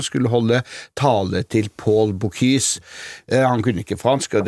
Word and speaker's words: Bocuse: I forkant av eh, årets Michelin-runde så har det vakt internasjonal debatt, så vidt jeg skjønner, at restauranten Paul Bocuse mista ei stjerne etter 55 år. Og forresten, jeg hører det litt annerledes, forskjellig. Hvordan uttales dette? Bocuse eller Bocuse? Du må for all del ikke si Buky.